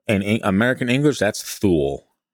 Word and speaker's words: Thule: I don't think you said that right. – Thule? – It's Thule.